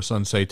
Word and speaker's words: Sun Tzu: So I think it was Sun Tzu, or Sun Tse technically pronounced, was the art of war.